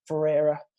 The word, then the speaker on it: Ferreira: My man, Steve Ferreira. Am I saying it right? The Portuguese? – Yeah, you got it. – Because if it's Italian, it's Ferrara, but they don't have the I in there, you know?